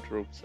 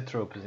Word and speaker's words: tropes: Mas se pronuncia Tropes, então. – É tropes, é.